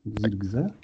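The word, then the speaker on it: Zirkzee: Y, debiutant, czyli Zirgze, tak się to wymawia? Zirgze? – Tak, raczej tak.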